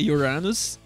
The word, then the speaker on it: Uranus: É Uranus. Quem não sabe, né? Em, em Urano em inglês é Uranus, que é a mesma pronúncia de seu anos.